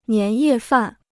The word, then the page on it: 年夜饭: nián yè fàn